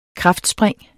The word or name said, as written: kraftspring